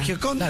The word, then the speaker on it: Gioconda: Gio, con G I O. – Está bien, sí, sí. – Conda. Claro, Gioconda.